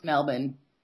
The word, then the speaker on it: Melbourne: It's like Melbourne. – Melbourne? – Excuse me, it's Melbourne.